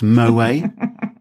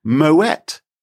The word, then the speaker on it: Moët: No, you don't, actually, because did you know it is not pronounced Moët? Even though it looks like, because it's French, you don't pronounce the final T. – No, Moët. Yeah. – No, mademoiselle. It is actually Moët because it's someone's name.